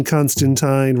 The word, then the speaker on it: Constantine: But the correct way is that John Constantine rhymes with wine, which is a line from a Swamp Thing issue, I believe.